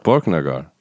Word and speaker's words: Borknagar: Borknagar.